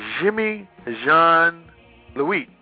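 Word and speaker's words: Jean-Louis: Jimmy Jean Lewis is John Lee. – Pardon? – French. Jimmy John Lee. – Oh, I'm sorry. I mispronounced it. Jimmy Jean Louis.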